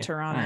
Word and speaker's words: Toronto: Or is it Toronto? That's it. You don't pronounce the last T. – Yeah, Toronto.